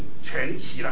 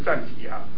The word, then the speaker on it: 传奇: 那在前一阵子在香港还有一个争论因为呢香港的那个电视台那个新闻的全部都把它读成全奇了，现在以前都是传奇那有一个争论，但是我不管了，因为我从小习惯了传奇，我就传奇了，好吧？就是今天晚上，明天晚上还有礼拜天早上我们就来讲同一个主题，就是生命传记。